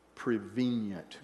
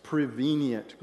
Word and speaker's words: prevenient: Today, I want us to take a look at prevenient grace. Say that with me. – Prevenient grace. – Prevenient grace. You can put an extra syllable in there if you want.